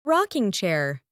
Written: rocking chair

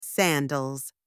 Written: sandals

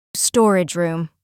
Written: storage room

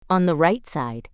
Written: on the right side

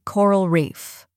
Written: Coral reef

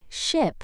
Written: ship

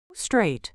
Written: straight

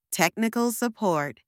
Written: technical support

